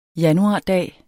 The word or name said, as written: januardag